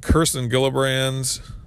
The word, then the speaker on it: Gillibrand: And then you got Kirsten Gillibrand, I believe is how you actually pronounce it.